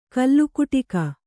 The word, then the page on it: ಕೋಲುಕುಟುಕ: kōlu kuṭuka